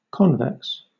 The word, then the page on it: convex: British English